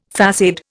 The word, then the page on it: façade: Indian